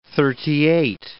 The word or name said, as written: thirty-eight